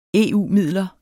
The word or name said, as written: EU-midler